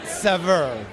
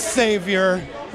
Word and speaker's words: Saveur: If I were in France, I would probably say Saveur. But since I'm in America, I say Saveur, and probably the strangest way that I've ever heard it pronounced is Savior, which I gotta say is a little weird, but also kind of wonderful.